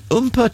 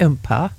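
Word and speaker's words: oompah: The Umpah Trio present Oktoberfest. – Is it Umpah? – Umpah. – Is that how you say it? – Yeah, Umpah.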